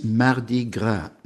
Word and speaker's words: Mardi Gras: In French, it is Mardi Gras, da, da, da, two syllables.